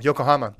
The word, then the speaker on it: Yokohama: I thought you said Oklahoma. – No, Yokohama. – Oh, okay. – Did I pronounce that wrong? – I think you did a good job.